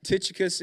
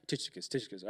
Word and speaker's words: Tychicus: Tychicus is how it is pronounced in the Greek. I had to look that up, had to play it several times to make sure I was saying it right. Tychicus. All right. So that's how you say his name.